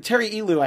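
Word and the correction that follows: Ilous: I hope I'm saying his name right. – Elus. – See, yeah. I was like, wait, no, I remember, because I talked to him, and it's been just long enough that I forgot how to say his name, Elus.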